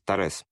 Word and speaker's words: Торез: Правильно я ударение Торез? – Да, да, в честь Мариса Тореза, главы французской коммунистической партии.